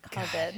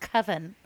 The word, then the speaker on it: Coven: Colvin. – And it's called Colvin. – Colvin. – They Colvin. – Pronounce it Colvin. Correctly pronounced Coven. God. It's pronounced Coven.